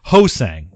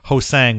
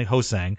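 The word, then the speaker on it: Ho-Sang: for making it clear that it is Hosang with the emphasis on the first part of his last name, not Hosang, which we had been calling him for a while. It's apparently Hosang, so you know that now.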